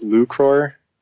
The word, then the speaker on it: lucror: So the handle is actually pronounced lucror, and it is a Latin word meaning to win or to profit.